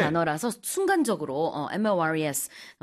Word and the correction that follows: mores: 이렇게 발음을 했었는데 사전적으로 이제 발음 기호를 찾아보시면은 Morays Okay. – 이렇게 나와 있긴 합니다.